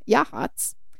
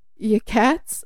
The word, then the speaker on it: Yachats: Also a town that Danielle and I absolutely butchered the name, pronouncing it Yakats, until one of the retreat goers informed us of how to actually say it.